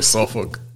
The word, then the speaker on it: Suffolk: Is it Suffolk? – yes. – that's how you pronounce it.